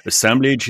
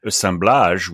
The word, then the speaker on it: Assemblage: Assemblage is perfectly fine by me. Assemblage will be a more French pronunciation. But again, the word works in at least two languages and is relevant in both.